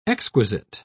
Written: exquisite

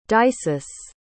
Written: dysis

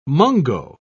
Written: mungo

